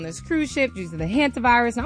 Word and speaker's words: hantavirus: Hantavirus.